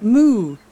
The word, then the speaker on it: moo: Moo. When we write it, it looks different. – Das habe ich schon mal gesehen. Mo, schreiben die. – Right, Danny, but we pronounce it moo.